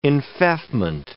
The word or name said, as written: enfeoffment